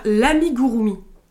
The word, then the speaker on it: amigurumi: Alors on va commencer par l'amigurumi.